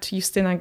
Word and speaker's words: Justyna: So you can see more of my journey as well on Instagram and on Twitter at Justyna Green, and you spell my name J-U-S-T-Y-N-A.